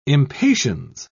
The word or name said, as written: impatiens